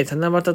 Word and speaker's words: 七夕: ちなみに七夕と書いて七夕と読むのは、